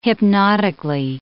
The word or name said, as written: hypnotically